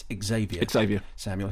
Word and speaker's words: Xavier Samuel: who is Xavier Samuel, though, as you're going to hear, he corrects my pronunciation, and it's Xavier Samuel, who plays Riley Byers, who was in The Good Life, of course.